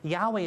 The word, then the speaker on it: Yahweh: because they're going from Hebrew and they're trying to put it into English, but Yahweh is the better pronunciation.